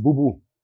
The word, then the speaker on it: Bubu: Die Budgetmarke ist dann die Bubu oder Bobo, wie spricht man sie aus? – Wen? – Welche ist die dritte Marke? – Die dritte Marke heißt Bubu.